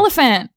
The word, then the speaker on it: Olyphant: Olyphant. – Yeah, sorry, Olyphant. Yeah, I can't say his name. I never know how to say it. Elephant.